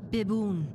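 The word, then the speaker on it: biboon: And you pronounce it Bibun. – Bibun.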